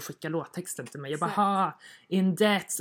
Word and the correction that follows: depth: Ja, that makes more sense. – Nej, alltså den är, jag säger get to know you in depth. – Ja, depth. Jag kan inte uttala det.